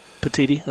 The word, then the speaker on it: Petitti: Is that how you pronounce his name, Petitti? – That is exactly how you pronounce it, yes. – Petitti, okay.